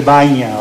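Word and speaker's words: bagna: O sea, nosotros, más que baña, voy a hacer una corrección, baña. Vamos a usar baña porque gen en italiano es pronuncia inne, para hacer una buena pronunciación, que no haya otros que dice están hablando mal.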